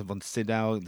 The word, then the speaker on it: Von Sydow: Von Sydow? – Yeah.